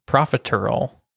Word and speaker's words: profiterole: Profiterole. That was awful. Oh, my gosh. – It's spelled profit. – Earl. – Like a casserole. Profiterole.